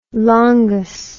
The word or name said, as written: longus